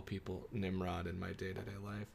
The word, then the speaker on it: Nimrod: It's nope, Nimrod. It's Nimrod.